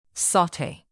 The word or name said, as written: saute